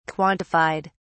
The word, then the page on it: quantified: British English